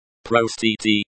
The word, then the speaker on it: простите: Простите, the O in pro is pronounced like the O in go. The sti is pronounced like the sti in steely. The t is pronounced like the t in t.